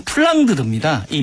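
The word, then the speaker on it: Flandre: 네, 그래서 이 플란다스 과거의 이름은 플랑드르입니다. 이 플랑드르가 영국식 발음으로 하면 플란다스의 개인데요.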